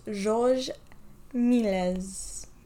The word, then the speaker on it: Georges Méliès: His name is Georges Méliès.